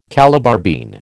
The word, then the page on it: Calabar bean: American English